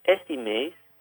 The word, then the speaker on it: este: One thing I'd like to note is the carioca is going to pronounce the este part differently. It's more of a sh sound. So it'd be este mês.